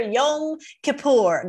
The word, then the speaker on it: Yom Kippur: Yom Kippur.